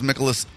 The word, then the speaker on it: Mikolas: Miles Mikolas out. Is that how you pronounce it? Miles... Mikolas? – Sure.